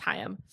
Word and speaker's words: Haim: I've been saying it wrong. It's Haim.